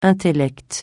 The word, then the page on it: intellect: francais